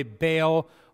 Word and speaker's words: Baal: And by the way, Baal, or if you want to pronounce it right, it's Baal, but that drives me crazy. I'm going to say Baal for the rest of my life, just to irritate people who have a better education and want, it's Baal.